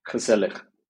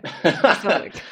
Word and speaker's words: gezellig: Gezellig. – Okay, I'm not okay. Gezellig.